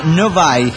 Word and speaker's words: Novi: Se pronuncia Novi y queda en Michigan.